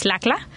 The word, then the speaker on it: LCLAA: It's mm-hmm. pronounced LACLA.